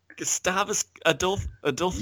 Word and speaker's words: Gustavus Adolphus: Astolphus Godulphus, or how do you say his name? How does his name go? – Gustavus. – Gustavus Adolphus. – Yeah, you nearly got it.